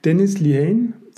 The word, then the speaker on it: Dennis Lehane: Dennis Lehane, Sekunden der Gnade. Spricht man den so aus? Ja.